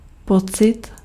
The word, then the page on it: feeling: /ˈfiː.lɪŋ/